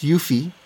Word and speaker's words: Eufy: And and it is pronounced Eufy, like you said.